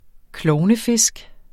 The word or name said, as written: klovnefisk